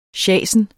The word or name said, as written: sjasen